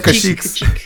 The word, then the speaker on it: Kashyyyk: It's like cash, you know. – Kashyyyk. – Yeah, you sounds like you're saying cheeks. – Cheek. You're on Kashyyyk.